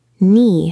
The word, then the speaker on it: Knee: Knee has a silent K.